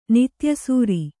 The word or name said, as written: ನಿತ್ಯಸೂರಿ